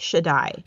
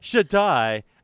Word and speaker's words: Shaddai: By the way, if you see Shaddai, that's pronounced Shaddai. – Shaddai.